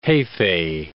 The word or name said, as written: jefe